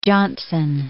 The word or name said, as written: Johnson